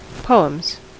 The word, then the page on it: poems: /ˈpoʊ̯.əmz/; /poʊ̯.mz/